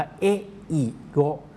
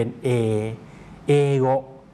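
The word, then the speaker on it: เ อเ อ โก (英語): ออกเสียงช้าช้าชัดชัดนะครับจะออกเสียงว่าเออิโกแต่ตรงเออิเนี่ยนะครับคนญี่ปุ่นออกเสียงรวบยาวเป็นเอเอโก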